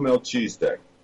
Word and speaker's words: Melchizedek: Drunvalo Melchizedek.